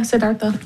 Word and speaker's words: Siddhartha: Yeah, Siddhartha.